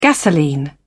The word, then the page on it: gasoline: British English